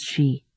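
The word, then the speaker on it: Xi: Pronounced Shi.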